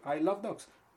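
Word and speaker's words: Isle of Dogs: bueno, y aparte y además, I Love Dogs, que Se pronuncia en inglés igual que I Love Dogs. Sí. Se pronuncia igual, son igual, la isla de perros y amo los perros.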